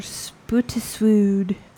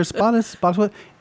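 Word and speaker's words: Spottiswoode: And it's written by Roger Spootiswood. – Why do you have to say it like that? Spootiswood. That's probably how he said it. Roger Spottiswood.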